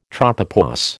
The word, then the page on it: tropopause: American English